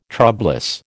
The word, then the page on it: troublous: American English